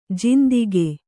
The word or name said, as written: ಜಿಂದಿಗೆ